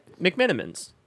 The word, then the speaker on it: McMenamin's: Am I saying that right? – McMenamin's. – McMenamin's? – Yeah, you got it. – Is it like cinnamon, but... – A little bit, yeah. – McMenamin? – But Irish. Yeah.